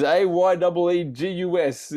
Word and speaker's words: Aegis: I think it's. Is it Aegis? Ages? Is it Ages? – Aegis. – That's what I'm going to go with. It is AYEEGUS. If you know how to pronounce that.